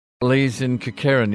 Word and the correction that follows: Kukerin: Is that right? Uh, pron- pronunciation, Lee? Uh, Kukerin. – Kukerin.